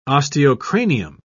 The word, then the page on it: osteocranium: British English